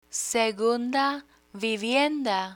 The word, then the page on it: segunda vivienda: spanish